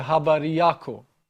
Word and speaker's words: Habari yako: Habari yako. Did I say it properly? – Of course, yeah.